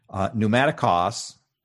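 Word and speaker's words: pneumatikos (πνευματικός): In Paul's writings, the word spiritual, okay, and Mary's may rebuke me on my pronunciation here, pneumatikos, okay, P-N-E, if you transliterate that into English, it's P-N-E-U-M-A-T-I-K-O-S, pneumatikos.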